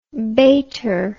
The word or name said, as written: baiter